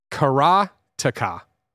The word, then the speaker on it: Karateka: Kebab says, Karateka is how I'm going to say it, is Japanese. So it would be pronounced Karateka.